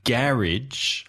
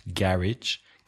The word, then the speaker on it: garage: For example, garage has the first syllable stressed, garage. Garage has the second syllable.